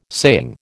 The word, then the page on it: saying: American English